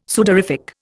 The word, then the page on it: sudorific: Indian